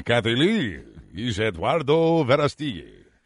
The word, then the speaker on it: Verástegui: How do you pronounce his last name? V-E-R-A-S-T-E-G-U-I. – Verástegui. Verástegui. Eduardo Verástegui.